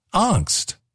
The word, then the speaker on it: angst: It's only five letters. It's five letters. Okay, what? How do you say A-N-G-S-T? A-N-G-S-T. And I said, angst. And he said, it's not angst. It's angst. It's a hard A or angst.